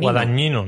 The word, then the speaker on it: Guadagnino: Yo, como, como, creo que tengo la mayor potestad de los tres para decir cómo se pronuncia esto, porque vengo de Sicilia y, he hablado con este hombre. guadagnino, vamos a dejarlo así.